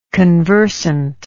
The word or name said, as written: conversantly